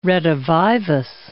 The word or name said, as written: redivivus